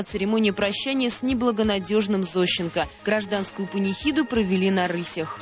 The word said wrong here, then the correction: на рысях: провели на рысях, конечно. Я прошу прощения за неправильное ударение.